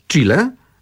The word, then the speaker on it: Chile: Chile to jest nazwa zapożyczona wprost z języka hiszpańskiego, a właściwie zapożyczona, znaczy do hiszpańskiego trafiła od Indian, z jednego z języków Indian, którzy stworzyli tę nazwę. I tutaj zapisujemy to CH, wymawiamy chi, to jest taki dźwięk, którego właściwie w języku polskim nie ma. On się pojawia tylko w niektórych wyrazach zapożyczonych, tak jak właśnie chile, czy na przykład chipsy, czy chill out.